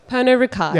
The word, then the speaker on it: Pernod Ricard: Pernod Ricard.